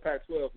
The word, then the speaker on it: Pac-12: a lot of Pac-12, a lot of Pac- I said it right, a lot of Pac-12 love there.